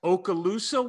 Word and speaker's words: Okaloosa: He played for the number one ranked Okaloosa Walton Junior College in Florida.